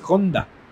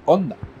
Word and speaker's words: Honda: Que no se dice Honda. Ok. Se dice Honda. La H es muda.